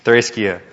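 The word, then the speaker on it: Threskia (θρησκεία): So I went to Google and I said, how do you say the word this? And it said it to me. So that's how I heard it. Threskia.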